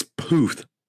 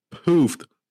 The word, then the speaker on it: Puth: Charlie Proof? – It's Puth. Puth. Say it with me. Puth. P-U-T-H.